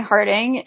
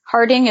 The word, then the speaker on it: Harding: My Twitter is at Cortney Harding, and Cortney is spelled C-O-R-T-N-E-Y. Harding is H-A-R-D-I-N-G.